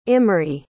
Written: imri

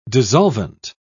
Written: dissolvent

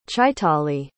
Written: chaitaly

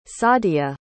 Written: sadia

